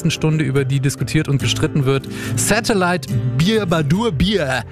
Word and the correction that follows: Bibadubi: Habe ich es richtig ausgesprochen eigentlich? – Bibadubi. – Ja, Bibadubi. Satellite hören wir jetzt erstmal und dann wird drüber gestritten hier.